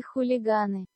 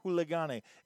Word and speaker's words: хулиганы: This is the Russian pronunciation of Queens and Hooligans. – Королевы и хулиганы. – Yeah, хулиганы. It even says хулиганы.